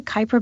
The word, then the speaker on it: Kuiper: It's headed out into the, how do you pronounce it, Kuiper Belt? K-U-I-P-E-R? – Yeah, I call it the Kuiper Belt.